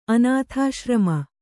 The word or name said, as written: ಅನಾಥಾಶ್ರಮ